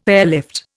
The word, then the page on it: airlift: Indian